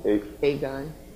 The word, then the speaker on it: Aegon: Aegon.